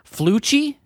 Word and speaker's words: Fulci: I think it's F-U-L-C-I, I believe. But who knows? I could be pronouncing it wrong. I always thought it was Fucci or Fulci. Fulci, some people say Fulci.